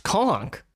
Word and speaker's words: conch: So he tweets us and say it's pronounced conch.